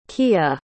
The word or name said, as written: kiah